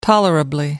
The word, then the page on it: tolerably: American English